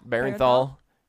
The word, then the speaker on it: Bernthal: Bernthal does not like alt-right fans, and that makes me happy.